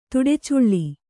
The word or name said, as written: ತಡಚಲ್ಲಿ